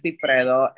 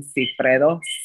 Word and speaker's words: Cifredo: But I'm on all the social medias as Joanna Cifredo, and that's spelled J-O-A-N-N-A, last name Cifredo, C-I-F-R-E-D-O.